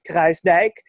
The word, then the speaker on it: Kruisdijk: Okay, so I will say it in the Dutch way so everybody knows how to pronounce it correctly. So my name is, my last name is Kruisdijk, and since it's so impossible, I've started picking up my second name as my last name, which is Charlotte.